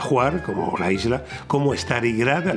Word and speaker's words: Hvar: Otra imprescindible y que me encanta se llama Juar, aunque se escribe H-V-A-R, pero se pronuncia Juar.